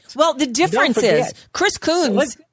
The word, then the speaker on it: Coons: Yes, if you believe absolutely that the likes of Chris Coons, that how they pronounce his name in Coons. – Delaware? – Yes, Coons.